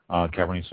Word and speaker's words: Cabernet: Cabernet, the T is silent, C-A-B-E-R-N-E-T, Sauvignon, S-A-U-V-I-G-N-O-N.